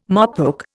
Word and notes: mopoke: Indian

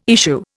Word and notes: issue: Indian

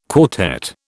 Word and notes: quartet: British English

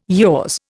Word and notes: yours: Indian

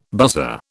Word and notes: buzzer: British English

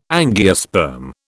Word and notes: angiosperm: British English

